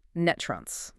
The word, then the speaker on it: NTREIS: And real quick, for anyone reading the source data or looking at that acronym, NTREIS, it is pronounced Netrunce. – Yes, it is. – It rhymes with rust.